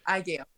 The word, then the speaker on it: AGEO: IGO Medics. – Makes sense. I'm pronouncing it like it's the friggin' Art Gallery of Ontario down the street, you know? AGEO. It's spelled AGEO. So AGEO is much better.